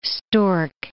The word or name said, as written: stork